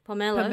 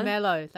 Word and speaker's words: pomelo: So the main gifts for this moon festival is usually the moon cakes and how do I pronounce that fruit? – Which one? Pomelo. – Pomelo, that's right.